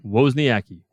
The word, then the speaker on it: Wozniacki: Wozniacki. – All right, I'm going to marry this Ashley Adams based on the fact that her name is hot as fuck. I can't say Wozniacki, so I'm going to kill her.